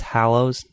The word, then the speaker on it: Hallows: It's Hallows, not Hollows.